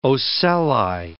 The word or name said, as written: ocelli